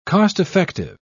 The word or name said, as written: cost-effective